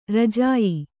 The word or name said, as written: ರಾಜ್ಯ